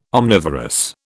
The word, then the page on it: omnivorous: British English